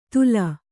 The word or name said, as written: ಧೂಲ